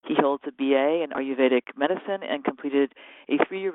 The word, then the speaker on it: Ayurvedic: Is it Vedic or Vedic? – Ayurvedic. – Ayurvedic, that's what I thought.